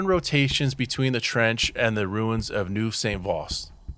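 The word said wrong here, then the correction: Neuville-Saint-Vaast: Sorry, I said that wrong again. Neuville Saint-Vaast.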